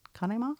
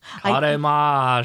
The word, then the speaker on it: Conemaugh: I forget the pronunciation you used, but trying to figure out how to spell it phonetically. Conemaugh? Is that right? Conemaugh? – Conemaugh. – Okay. – Conemaugh, Shaktite. Conemaugh, Shaktite. – Conemaugh. I think would be how I grew up saying it,